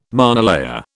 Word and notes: monolayer: British English

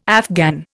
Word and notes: Afghan: Indian

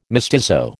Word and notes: mestizo: American English

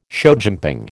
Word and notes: showjumping: American English

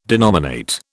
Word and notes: denominate: British English

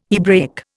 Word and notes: Hebraic: Indian

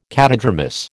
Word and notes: catadromous: American English

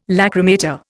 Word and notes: lachrymator: Indian

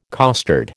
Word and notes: costard: American English

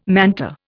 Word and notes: manta: Indian

